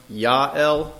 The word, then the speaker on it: Jael: her name is pronounced Ya'el, but for the sake of just making it easy for me, I'm going to call her Jael. And Jael went out to meet Sisera and said to him, "Turn aside, my lord, turn aside to me, do not fear."